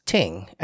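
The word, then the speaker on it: ting (聽): So in traditional Chinese, the verb to listen is pronounced ting, and that literally in traditional Chinese means to listen.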